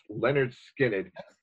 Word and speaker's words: Lynyrd Skynyrd: Pronounced Lynyrd Skynyrd, and listen, if on your debut album you have Freebird, that's pretty good.